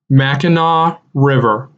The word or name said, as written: Mackinaw River